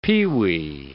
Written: peewee

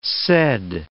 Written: said

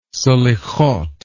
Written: selihoth

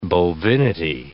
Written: bovinity

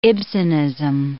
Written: Ibsenism

